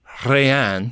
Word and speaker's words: Hraiyan: The name that he gives is spelled H-R-A-I-Y-A-N. – Yeah. – But he pronounces it Rayan, mm-hmm. – which sounds a lot like the French for nothing.